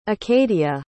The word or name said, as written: acadia